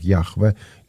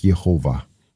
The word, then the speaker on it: Яхве: Вот этот тетраграмматон, то есть это сочетание из четырех букв, Яхва, да, мы с вами об этом уже как-то говорили, которое в современных вариантах озвучивается либо как Яхве, либо как Йехова, вот Йегова.